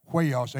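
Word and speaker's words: huios (υἱός): See, that word sons there is the word, I believe it's the way to pronounce it, huios, H-U-I-O-S, and it means maturity.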